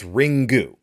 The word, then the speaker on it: Ringu: And so it's three symbols. It's ri, it's n, which is one of the few sounds that they have that doesn't include a vowel sound. It's just an n. So it's ri, n is the second symbol, and then gu is the third symbol. So literally translated, it's ringu. But yes, they're just trying to say ring.